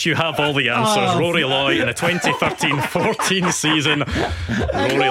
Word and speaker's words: Rory Loy: Roy, is it Roy Loy? How do you pronounce that? – Rory Loy. Goodness me.